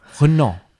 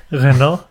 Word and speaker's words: Renault: Renault. Renault. Creo que sí, ¿no? Porque la R sería como una G.